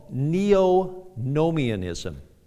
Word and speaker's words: neonomianism: Saints, there is a Christian heresy called neo-nomianism. Neo-nomianism. Let me spell that for you. N-E-O-N-O-M-I-A-N-I-S-M.